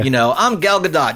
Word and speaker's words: Gal Gadot: But it's Gal Gadot, right? – Gal Gadot.